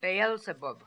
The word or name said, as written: Beelzebub